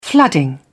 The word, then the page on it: flooding: British English